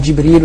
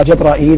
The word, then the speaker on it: جبريل: يقال جبريل ويقال جبرائيل ويقال جبرائيل وكلها واسعة. وأنا أحيانا يغلب علي أن أقول جبرائيل.